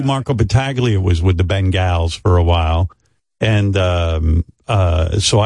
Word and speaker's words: Bengals: The official pronunciation is, Robin's right, Bengals, like Ben Stern, Bengals, Bengals. So from now on, everyone will say the Cincinnati Bengals.